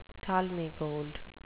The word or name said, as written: talmi gold